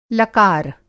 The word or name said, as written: लकार